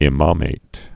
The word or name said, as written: imamate